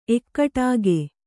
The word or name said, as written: ಏಕಟಾಕಿ